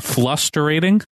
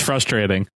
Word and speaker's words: frustrating: I heard this too, and Fessy said flusterating. I heard that too. He like mispronounced frustrating. I heard that. I was like, did he edit an L there instead of an R?